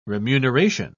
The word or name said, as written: remuneration